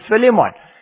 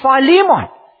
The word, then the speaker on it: Philemon: In South Africa, we pronounce it Philemon. But it's Philemon.